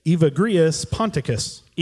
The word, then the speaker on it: Evagrius Ponticus: Evagrius Ponticus. – Evagrius Ponticus.